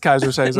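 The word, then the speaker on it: Keyser Söze: It is Kaiser Soze.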